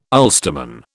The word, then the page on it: Ulsterman: British English